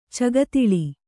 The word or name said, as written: ಚಗತಳಿ